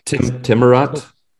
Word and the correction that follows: Tawaret: Tawaret. – Tawaret. Yeah, I looked it up.